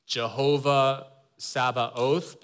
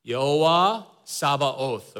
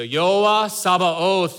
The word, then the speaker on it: Jehovah Sabaoth: But in Hebrew, it reads, okay, in American, I guess, in the pronunciation that we tend to use is Jehovah Sabaoth, but I'm gonna go back to my seminary training days, okay? I'm gonna pronounce it as Yehovah Sabaoth, okay? That way I feel better about myself. So Yehovah Sabaoth, that's how the Hebrew reads, okay?